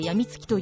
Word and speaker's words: 中毒: 中毒と書いて病みつきと読む